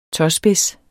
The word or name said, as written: tåspids